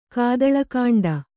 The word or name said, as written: ಕಾದಳಕಾಂಡ